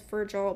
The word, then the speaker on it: Virgil: I believe his name is pronounced Virgil, but he...